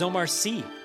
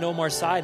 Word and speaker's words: Omar Sy: One of the best things I learned from that clip was that the character of Barry, his name is Omar Sy. Colin says it right there in the clip. I've been pronouncing it Omar Sy this whole time, so sorry, Omar. I've been pronouncing your name wrong.